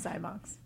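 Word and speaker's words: Xymox: How do you say it? – Yeah, Clan of Zymox.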